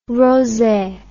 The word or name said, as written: rosay